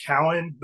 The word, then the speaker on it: Keown: In Tennessee, I guess, first of all, it's my last name's actually pronounced Cowan.